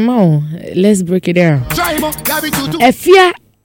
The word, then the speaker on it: Efya: But her stage name is Efia, that is spelled E-F-Y-A.